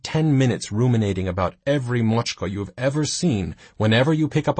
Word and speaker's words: macska: The spelling, M-A-C-S-K-A, the pronunciation, Mochka, how you'd use it in a sentence, 1,000 images of each Mochka you've ever met.